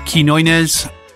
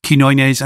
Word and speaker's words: Quiñones: I have to confess, I was stumbling over Joey's surname when I introduced that track, Quiñones, and it's spelled Q-U-I-N, N with a tilde, O-N-E-S, and the tilde is, it's a little mark that goes above a letter to change its pronunciation.